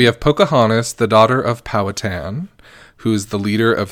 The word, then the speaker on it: Powhatan: Let's find his pronunciation. Here we go. Powhatan.